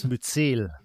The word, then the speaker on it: Myzel: Ich lese einmal vor, Myzel, sagt man das, spricht man das so aus beim Pilz? – Myzel. – Myzel, ah, Entschuldigung.